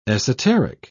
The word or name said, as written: esoteric